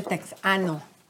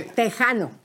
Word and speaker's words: tejano: No se dice texano. – Tex, no, pero él tiene. – Tejano.